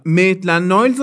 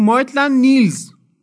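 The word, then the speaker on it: Maitland-Niles: و میتلن نایلز رو میگفت مایتلن نیلز نیلز کیه اصلا حالا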